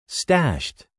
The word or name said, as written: stashed